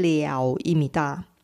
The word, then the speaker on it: 了: 이럴 때 러가 아니고 발음은 了입니다. 예를 들어서 他的女朋友漂亮的不得了.그 남자의 여자친구가 무지 예뻐요. 이해하다로 쓸 때도 발음이 려입니다.